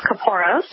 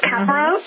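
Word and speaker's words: kapparos: Well, there are several pronunciations. We tend to stick to the pronunciation of caporos, but you might hear others refer to it as caparos or caporos. So you'll hear several incarnations depending on who you speak with, but we tend to just say caporos.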